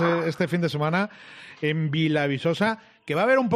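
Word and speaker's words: Vila Viçosa: Aquí en el Alentejo, muy cerquita de la raya, Vilavisosa. – Oh, qué ahí bien vamos. Lo dice, José Luis. – qué bien pronuncia.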